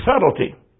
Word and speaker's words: subtlety: 2 Corinthians 11:3, "But I fear lest by any means, as a serpent beguiled Eve through his subtlety." Hey, you English students, don't pronounce that B.